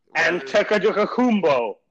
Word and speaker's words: Antetokounmpo: Not even gonna attempt to say his last name, Antetokounmpo or Antetokounmpo. – Whatever. That's how you say it.